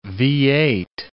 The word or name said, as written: V-8